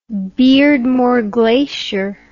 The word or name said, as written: beardmore glacier